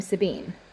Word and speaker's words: Sabine: Wait, just to double check, do you pronounce her name Sabine? – Yeah. – I've only ever known a person with that spelling to pronounce the name Sabina.